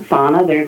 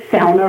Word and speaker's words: sauna: There's a sauna road, which, of course, in this area, you do not pronounce it sauna. That's not a word. It doesn't exist. It is sauna road.